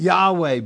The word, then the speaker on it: Yahweh: But Yahweh, but it probably is the right pronunciation instead of Jehovah.